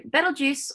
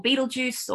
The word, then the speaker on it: Betelgeuse: So Betelgeuse or Betelgeuse or any number of other pronunciations, forgive me when I inevitably switch between Betel and Beetle.